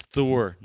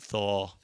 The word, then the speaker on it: Thor: It's Thor, not Thaw.